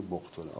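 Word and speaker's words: بقطر: این شاید یک نامه دیگری بوده که مسلم نوشته که در واقع واسطه این پیک عبدالله ابن بقتر که گاهی اشتباه هم تلفظون یقتر میخونم سال اینها زبط صحیحش عبدالله ابن بقتر.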